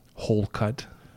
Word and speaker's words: Holcutt: Hole cut.